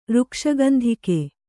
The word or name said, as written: ಋಕ್ಷಗಂಧಿಕೆ